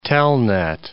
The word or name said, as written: telnet